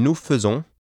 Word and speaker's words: faisons: Notice also that the AI in nous faisons is pronounced a little like the U in the English word burn. This pronunciation is irregular for the AI spelling, and although not difficult to pronounce, must be memorized.